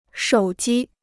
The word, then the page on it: 手机: shǒu jī